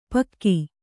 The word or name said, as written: ಪಗ